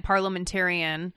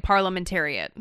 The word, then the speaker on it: parliamentarian: Everybody who called mispronounced it. Not just Jeff, everybody who calls mispronounces it. – For the first day, I kept calling it parliamentariat.